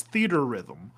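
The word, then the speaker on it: Theatrhythm: Is it Fiat Rhythm? Theater Rhythm? – I think it's Theater Rhythm. – Theater Rhythm. Okay.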